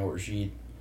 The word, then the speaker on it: orgeat: We make our own orgeat. Um, that's how you pronounce that. – Okay. Yeah. Oh, it's said uh, orgeat.